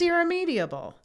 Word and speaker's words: irremediable: Side note, I was also pronouncing it irremedial. Oh. It's irremediable. It's a harder word to say.